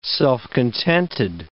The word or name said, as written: self-contented